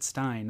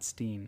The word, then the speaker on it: stein: And I think they said, I think you pronounce it Stein, not Steen.